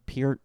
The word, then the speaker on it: Peart: It's like Neil Peart or Pert, depending on how you say it.